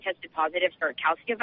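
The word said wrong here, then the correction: calici: virus. I think that's how you pronounce it. – Khaleesi, but yes, close enough. – Khaleesi virus, thank you.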